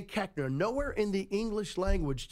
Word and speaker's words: Koechner: Koechner.